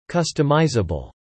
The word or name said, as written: customisable